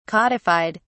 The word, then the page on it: codified: British English